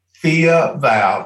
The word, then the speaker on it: Thea Val: Thea Val, T-H-E-A Val.